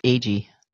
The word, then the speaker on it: Agee: I just want to mention the screenplays by, I'll get the name wrong, but James Agee, A-G-E-E. – Agee, they say.